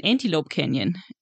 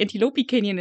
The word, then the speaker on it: Antelope Canyon: Leute nach dem Antelope Canyon gefragt habe und alle dann so, du meinst den Antelope Canyon? Und ich so, ach so, so wird er ausgesprochen.